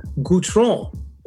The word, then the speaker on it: Guitron: I am with the talented Ericka Guitron.